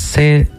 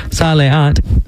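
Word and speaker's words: Saleat (סע לאט): We're going to be playing a classic of his called Saleat. No, I said that wrong. Saleat, which translates to drive slowly.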